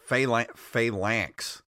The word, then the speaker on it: Phalanx: And apparently I'm pronouncing it wrong. It's Phalanx. Phalanx is how it's pronounced.